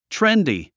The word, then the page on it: trendy: tréndi; トレンディ